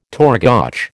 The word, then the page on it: torgoch: American English